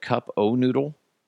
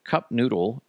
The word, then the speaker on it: Cup Noodle: Do you say cup of noodle or cup noodle? I always said cup o noodle, because I need to research this, because I don't know. Apparently, cup noodle is the proper way to say it.